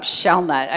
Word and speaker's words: Shellnut: How do you really say it? – You did a great job, Shellnut.